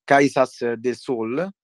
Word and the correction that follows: Caxias do Sol: Dove... – Quasi, quasi, quasi l'hai seccata. Caxias do Sol si dice.